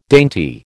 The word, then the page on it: dainty: American English